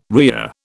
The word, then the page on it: rhea: British English